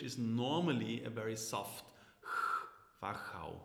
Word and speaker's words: Wachau: How's my pronunciation? Wachau. – Wachau, perfect. That's really good.